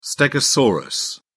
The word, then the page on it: stegosaurus: British English